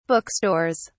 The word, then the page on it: bookstores: British English